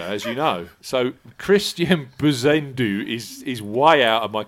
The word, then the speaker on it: Bezuidenhout: It's how do you pronounce Christian Bezuidenhout? Paul. Say that again. – Bezuidenhout. – Bezuidenhout. – Or something very similar to that. They do tend to mask it on the commentary as well, don't they? But yeah, as I understand it, it's Christian Bezuidenhout. – Is it? So it's not a silent T. It's not a silent T at the end, no? – But it might be, yeah.